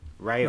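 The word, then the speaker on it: Raúl: They brought in guys like Real, Raul, or however you say his name. – Raul. Raul, right? – Yeah, yeah, and uh, Ren- and Brazilian Ronaldo.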